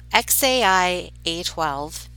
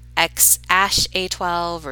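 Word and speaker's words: X Æ A-12: So Grimes said, it's just X like the letter X, then AI, like how you said the letter A and I, and then followed up with A12. So XAI A12, but Elon said, I mean, it's just X, and then the AE combo is pronounced like ash and then A12. So X ash A12 or Zash A12 is what he's saying.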